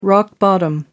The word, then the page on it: rock-bottom: American English